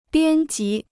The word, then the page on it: 编辑: biān jí